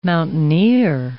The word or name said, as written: mountaineer